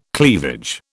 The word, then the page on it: cleavage: British English